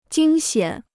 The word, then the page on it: 惊险: jīng xiǎn